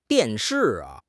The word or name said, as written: dian4 shi4